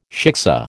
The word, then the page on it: shiksa: American English